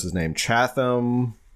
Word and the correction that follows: Chamath: Chamath, who's partial owner of the Warriors.